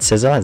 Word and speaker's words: SZA: Is it SZA? Is that how you pronounce her name? – Yeah.